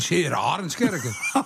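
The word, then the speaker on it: 's-Heer Arendskerke: Je begint steeds Zeeuwse te praten. – Ja, maar zo spreek je dat uit. Seer Arendskerken.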